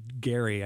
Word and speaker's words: Gerry: Yeah, I mean, gerrymandering, it's named after a guy, Eldridge Gerry, I think it's pronounced with a hard G, as I understand it.